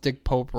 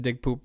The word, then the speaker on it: Dick Pope: I'll say, like, the world is a bunch of seventh graders, because this woman, okay, it's kind of funny, she pronounced Dick Pope wrong. She said Dick Poop.